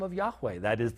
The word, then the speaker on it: Yahweh: In Hebrew, the name used in the Old Testament over 6,000 times is Yahweh. We slaughter that in English as Jehovah. That's not how it could have been pronounced by the Jewish people. But Yahweh is probably the best pronunciation of the divine name.